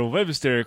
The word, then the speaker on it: Webster: Webster, como é que fala, Guilherme? – Fala Webster mesmo.